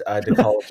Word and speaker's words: de Collibus: That's one way to pronounce it. Usually it's just de Collibus.